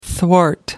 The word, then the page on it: thwart: American English